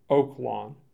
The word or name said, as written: Oak Lawn